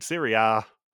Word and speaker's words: Serie A: And their second question is to name teams in Serie A. In Serie A, I should say. Do it the proper Italian pronunciation.